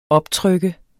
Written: optryk

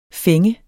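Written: FÆNG

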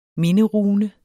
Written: MINDERUNER

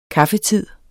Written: kaffetid

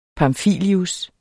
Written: pamfilius

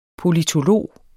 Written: POLITOLOG

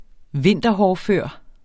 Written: vinterhårdfør

